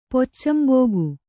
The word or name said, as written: ಪೊಚ್ಚಂಬೋಗು